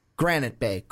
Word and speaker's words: Granite Bay: Jake is from Garnet Bay. I think I said that right. Granite Bay. That's how you say it.